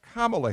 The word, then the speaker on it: Kamala: Number one, her name is Kamala Harris, and I've noticed that for some reason there are certain Republicans who have chosen to mispronounce the name, maybe accidentally.